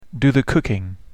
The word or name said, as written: do the cooking